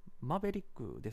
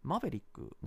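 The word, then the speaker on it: Maverick: ですけど、なんでマーヴェリックなんだろうって泣きになっていて、あの、いや、これ単に英語の話で、綴りを見ても、 MA、えと VELIK ですか、 R 入ってないです、なくて、えっ、と、マベリックですし、まあ、発音実際聞いても、で、発音記号もチェックしたんですけども、やっぱこれ、マベリックなんですよね。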